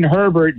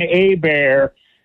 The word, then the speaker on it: Herbert: You had a bozo call about 10 minutes ago that pronounced Justin Herbert Justin A-Bear, that doesn't understand head coaches coach to win this Sunday and don't look any further than that.